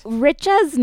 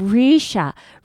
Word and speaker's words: Richa: Like, Richa's name is spelled R-I-C-H-A. – Right. – It's literally Richa, phonetically. And he kept being Risha over and over again.